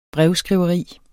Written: brevskriveri

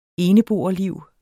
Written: eneboerliv

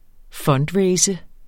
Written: fundraise

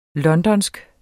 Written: londonsk

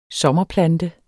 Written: sommerplante

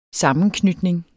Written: sammenknytning

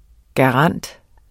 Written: garant

